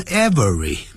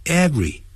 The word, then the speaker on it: every: 好了，但是有时候我们就不能加任何的介词，比如说用 every 开头的时候， every 也是两个音节，不注意时会念成 every, 没有这个念法。 every, every, every, every morning, 用 every 开头的这种时间副词短语，它前面不能放任何的介词的。